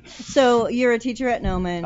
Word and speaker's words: Gnomon: the Noman Workshop, which is spelled G-N-O-M-O-N.